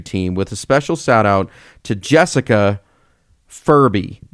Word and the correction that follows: Burby: Burby. Jessica Burby rhymes with Furby.